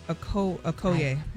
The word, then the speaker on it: Okoye: Okoyoke? O-K-O-Y-E? – Oh, my God. Did anybody say it right? – I think it's Maduka Okoye. – No idea. – Really? Should break it down. – Maduka Okoye, Gary.